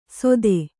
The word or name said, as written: ಸೊಥೆ